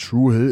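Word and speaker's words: Dru Hill: Und True Hill ist sozusagen die lokale Ausspracheform dieses Parks.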